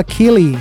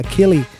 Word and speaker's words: Keley: No todo encaja con el bailar pegados, pero acá les tengo a Kelly, se escribe K-E-L-E-Y, Kelly, un nuevo single llamado Sing Everything.